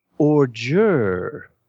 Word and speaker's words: ordure: It's not spelled like it's pronounced. Does anyone have an idea what this word means? – I'd have to see it spelled. I hate to say it. – O-R-D-U-R-E. – Orgure. – Sounds like you're saying an odor. – Yeah, it's not. I've got it. – The D is actually a J.